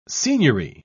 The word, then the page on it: seigniory: British English